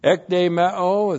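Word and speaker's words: Ek de meo (ἐκδημέω): Ek de meo is the same verb we had in verse 6. Looks like that in the Greek, E-K-D, long E-M-E, long O. Ek de meo.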